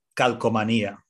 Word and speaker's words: Calcomanía: Calcomanía, the first Spanish word of the day.